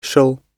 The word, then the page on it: shall: British English